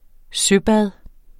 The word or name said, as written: søbad